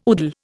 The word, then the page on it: udal: Indian